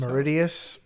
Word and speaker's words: Mauritius: They may be found around Mauritius. I feel like I'm saying that wrong. – Mauritius? I think it's Mauritius. Yeah, that looks better. That sounds better.